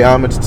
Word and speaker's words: homage: Why they refer to it as Lynching Museum is because it is a place where they pay homage to. Did I say that right? – Yeah. – Pay homage to and they also celebrate.